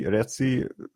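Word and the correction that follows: Raci: biztos így ejtik, bocsánat, Racy. – Racy, és Licky Stanfield, Julius and the Black Messiah.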